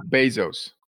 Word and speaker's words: Bezos: Long A, long O, Bezos. – Long A, long O, it's an E. Bezos. – I know, but that's the sound of how you pronounce it.